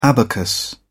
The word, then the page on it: abacus: British English